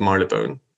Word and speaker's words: Marylebone: Yeah, so the Swiss embassy is in the middle of Marylebone which you pronounce very well, by the way.